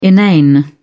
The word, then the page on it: inane: British English